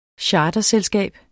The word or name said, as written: charterselskab